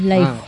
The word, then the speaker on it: Laiho: No sé cómo se pronunciará el finés, Laiho o Laiho. Ah, Laiho, ¿sí? Tengo entendido que es Laiho.